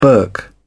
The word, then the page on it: burk: British English